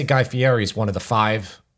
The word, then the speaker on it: Fieri: Yeah, it's so Fieri, he says his name. – But he pronounces it Fieri with like rolling the R, like the Italian pronunciation.